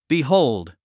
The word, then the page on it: behold: bihóuld; ビホウルド